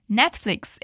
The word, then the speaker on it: Netflix: Netflix é a pronúncia correta em inglês porque a gente chama de Netflix. Então, repeat: net. Flix. Netflix good.